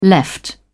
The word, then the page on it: left: British English